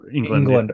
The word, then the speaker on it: Englund: Am I pronouncing England. – Yeah. Oh, so just like the country pronunciation.